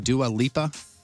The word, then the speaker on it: Dua Lipa: The rest of the top five are new albums from Bad Bunny, Bob Dylan, and that, am I saying it right, Dua Lipa? – Yeah.